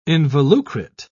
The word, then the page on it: involucrate: British English